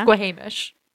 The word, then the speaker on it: Squamish: Squamish. But yes. – Right. Yeah, that's what I said. Yes. I must have pronounced it wrong. The emphasis was wrong. – You pronounced it a little incorrectly, but close. Close.